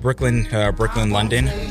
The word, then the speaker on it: BKLYNLDN: It's pronounced Brooklyn, London, but it's spelled B-K-L-Y-N-L-D-N.